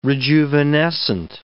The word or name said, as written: rejuvenescent